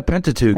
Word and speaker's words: Pentateuch: the first five books of the Bible are called the Pentateuch. Is that how you pronounce That's exactly it? right. Yeah, Pentateuch.